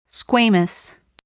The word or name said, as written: squamous